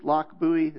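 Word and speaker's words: Lochbuie: It is pronounced Lock Bowie. It's kind of strange.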